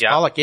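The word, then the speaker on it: Polak: There's one of, I'm not sure if I'm pronouncing the name correctly, Vosik Pollock. – Yes, Pollock. Yeah.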